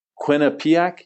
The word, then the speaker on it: Quinnipiac: Quinnipiac University in Connecticut. How'd I do? – Yeah, you got it. All right. – So first of all, tell me a little bit about Quinnipiac and what sort of school it is.